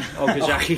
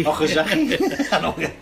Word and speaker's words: Okazaki: I'm glad you pronounced that before me, actually. Okazaki. – Okazaki.